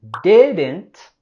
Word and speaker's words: didn't: Queremos subir la voz y entonación en la primera sílaba de didn't, y tenemos el sonido stop T en didn't. Okay, vamos a parar el aire, no dejarle que se va totalmente por la boca a decir didn't.